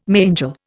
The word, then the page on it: mangel: Indian